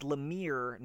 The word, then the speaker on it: Lemire: I believe it's Lemire, not Lemire.